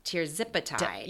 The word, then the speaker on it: tirzepatide: Tirzepatide. – Tirzepatide.